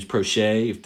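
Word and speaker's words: Proche: They added a lot of speed with Devin Duvernay out of Texas, who's a track guy, and then James Prochet, did I pronounce it right, the SMU you did.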